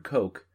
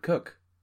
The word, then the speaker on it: Coke: who I've referred to as Edward Coke, but whose name is really Edward Cook.